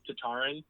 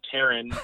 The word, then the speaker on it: Tataryn: I was brought up Tataryn, and then my dad broke my heart like two weeks ago and told me it's actually pronounced Taran, but we've been pronouncing it Tataryn. Uh, so I'm just going to keep running with that. It's Tataryn.